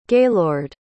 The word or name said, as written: gaylord